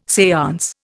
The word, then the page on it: seance: Indian